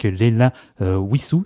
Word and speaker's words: Leila Wissou: Là, ici, on va partir en musique avec Leila Wissou, c'est comme ça que ça se prononce? – Oui, tout à fait, qui présente son nouveau spectacle au mois de novembre.